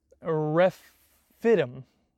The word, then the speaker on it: Rephidim: And they camped at Rephidim. You get that? I think that's exactly how you say it.